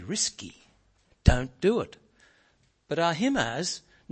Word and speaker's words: Ahimaaz: And so this passage, and here we come to verse 19, Ahimaaz. Is that how you say it, Milton? Ahimaaz? Ahimaaz.